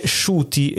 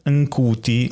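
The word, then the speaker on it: Ncuti: Dice si legge Shuti e non Nkuti.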